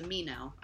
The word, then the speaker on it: Minho: That area is known as the Minho, or the Mino. I'm assuming you don't pronounce the H. – You're right. I was definitely pronouncing the H.